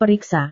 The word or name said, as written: periksa